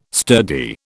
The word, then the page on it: sturdy: British English